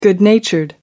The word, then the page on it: good-natured: American English